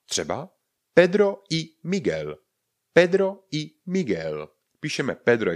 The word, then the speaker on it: Miguel: M-I-G-U-E-L. Víme, že G-U-E vynecháme U ve výslovnosti a zůstane nám tam G. Ale není to G, to by tam to U nesmělo být.